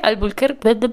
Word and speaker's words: Albuquerque: Albuquerque, ese, mero.